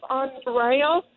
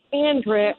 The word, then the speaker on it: Andria: The way they pronounce my name is Andrea, and they spell it wrong, too. – Is it Andrea, though? – Yes, it's Andrea, A-N-D-R-I-A, and they spell my name A-N-D-R-E-A. They say Andrea.